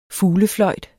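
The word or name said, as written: FUGLEFLØJT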